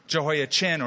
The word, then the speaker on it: Jehoiachin: Jehoiachin, or in Hebrew, Jehoiachin would be the pronunciation, is on the throne 3 months and 10 days.